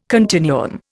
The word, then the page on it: continuum: Indian